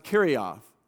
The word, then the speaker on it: Kerioth: That's the place he's from, a small town in Aramaic pronounced Kerioth.